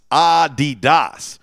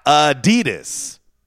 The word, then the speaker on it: Adidas: Americans pronounce Adidas wrong, okay? It's supposed to be Adidas is how it's really supposed to be pronounced, with a slight emphasis on the first syllable, ah, not Adidas. The stressing of the second syllable is incorrect.